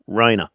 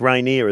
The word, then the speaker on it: Rainier: I didn't know it was actually pronounced like that. Because it was raining, I just thought Rainer. But it's Rainier, is it? – Yeah.